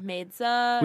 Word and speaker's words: Maidza: How do you say her last name? Maidza. – Maidza.